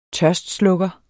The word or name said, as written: tørstslukker